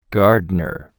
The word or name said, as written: gardener